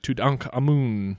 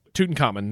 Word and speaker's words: Tutankhamun: Watching the videos of this, listening to stuff, the Egyptologist pronounce it Tutankhamun. – Yep. – Tutankhamun. That's what everybody says.